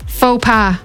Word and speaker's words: faux pas: Faux pas. – Spot on. Didn't pronounce the X or the S.